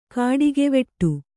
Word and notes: ಕಾಡಿಗೆವಿಡಿ: kāḍigeviḍi

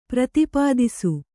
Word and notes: ಪ್ರತಿಪಾದಿಸು: prati pādisu; kannada

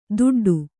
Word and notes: ದುಡ್ಡು: duḍḍu

